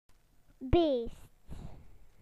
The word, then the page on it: wild animal: British English